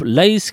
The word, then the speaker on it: Laïs: Aujourd'hui, j'aimerais moi vous parler du premier album du groupe Laïs, qui s'écrit L-A-Y-S, qui est un groupe belge et pour préciser, un groupe flamand composé de trois chanteuses.